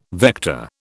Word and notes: vector: British English

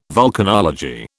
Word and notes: vulcanology: British English

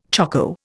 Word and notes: choko: Indian

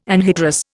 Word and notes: anhydrous: Indian

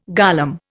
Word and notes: golem: Indian

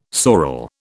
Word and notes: sorel: British English

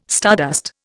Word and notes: stardust: Indian

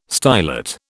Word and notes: stylet: British English

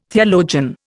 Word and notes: theologian: Indian

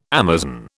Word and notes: Amazon: British English

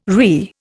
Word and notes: RI: Indian

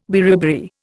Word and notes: beriberi: Indian

